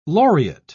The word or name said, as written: laureate